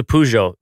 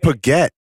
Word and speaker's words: Peugeot: P-U-U. – Oh, that's what you call them? – G-O. Oh. – I called it Paget. – Okay. – That's not Paget. – I called those just Paget. – It's a Peugeot.